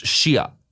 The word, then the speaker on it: Shia: Like, you say it, it's pronounced Shia.